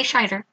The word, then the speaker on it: Scheider: I never know if it's Schneider or Scheider. It's spelled Scheider. So I'm going to say Scheider.